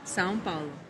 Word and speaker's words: São Paulo: São Paulo.